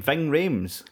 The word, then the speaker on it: Ving Rhames: Ving Rhames.